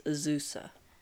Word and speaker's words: Azusa: Next is Azura, Lost But Seeking. – It's Azusa. – I always get these cards wrong.